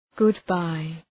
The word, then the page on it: goodbye: gʋd’baı